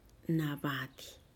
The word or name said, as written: nabadih